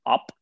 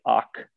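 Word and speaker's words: ốc: And it's like op, but they don't pronounce like the C like OC, it's op.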